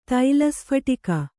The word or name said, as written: ತೈಲಸ್ಫಟಿಕ